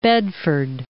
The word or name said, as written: Bedford